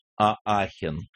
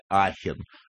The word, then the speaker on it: Аахен: Игорь 747 и Костя 966 правильно назвали город Аахен или Ахен, как угодно, или Экс-Ля-Шапель, как говорят французы, но именно там и похоронен Карл Великий.